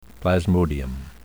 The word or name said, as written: plasmodium